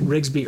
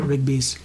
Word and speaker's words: Rigby: It's Rigby. I mispronounced it. I said Rigsby earlier.